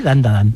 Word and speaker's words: Dandadan: Tenemos Dandadan, creo que lo he dicho bien, Dandadan. – Sí, Dandadan.